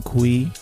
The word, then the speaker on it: Cui: And of course, you could find me, Brian Kui, last name is spelled C-U-I, on LinkedIn.